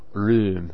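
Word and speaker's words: Röhm: Whatever anti-homosexual sentiments may have been expressed by straight Nazis were more than offset by the reality of highly visible, spectacular, gay-loving Rome. Rum is how it's actually pronounced.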